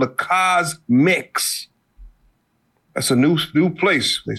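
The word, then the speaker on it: CosMc's: Cosmix.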